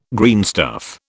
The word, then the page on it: greenstuff: British English